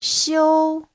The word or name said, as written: xiu1